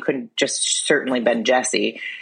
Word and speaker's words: Jussie: Jussie.